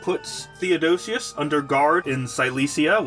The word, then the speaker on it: Cilicia: I wrote myself a pronunciation guide. It is pronounced Cilicia.